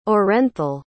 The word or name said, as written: orenthal